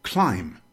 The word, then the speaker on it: climb: Here are some common words that are spelled with a B, but not pronounced with a B. First, we have climb, to move upward, usually using the hands and feet.